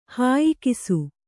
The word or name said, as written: ಹಾಯಿಕಿಸು